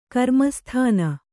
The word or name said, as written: ಕರ್ಮಸ್ಥಾನ